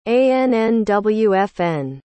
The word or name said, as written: annwfn